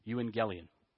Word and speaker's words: euangelion: euangelion, and it means good news.